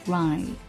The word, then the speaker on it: ride: 小连读，Let's ride。 Let's 字母 T S 组合的发音，Let's ride。 Ride。 R I D E。我以前有听过小伙伴读成， red。因为很多小伙伴就是在发音上哈、啊，双元音 i 跟梅花音 a 弄得有点混乱。